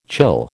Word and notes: chill: American English

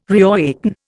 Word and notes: reawaken: Indian

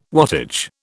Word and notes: wattage: British English

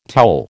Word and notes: towel: American English

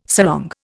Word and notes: sarong: Indian

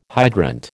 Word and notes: hydrant: American English

